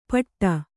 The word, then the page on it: ಪಟ್ಟಾ: paṭṭā